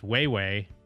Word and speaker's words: Wawa: From the Ojibwe word... pronounced Weiwei, meaning snow goose.